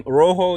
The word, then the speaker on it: Roho: But yeah, Rojo, it's spelled R-O-H-O.